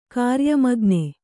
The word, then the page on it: ಕಾರ್ಯಮಗ್ನ: kāryamagna